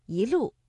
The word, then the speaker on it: 一路: 正确的声调应该是第一句的“ 一路霜林”，我们的“ 一” 读二声，一路。